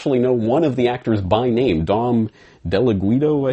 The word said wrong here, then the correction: Ghiaccio: I think was his name. Let me actually get that correctly. I haven't noted down here. – Yeah. Del Ghiaccio. – Del Ghiaccio, mm-hmm. Yes.